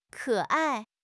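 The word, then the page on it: ke3 ai4: tone 3; tone 4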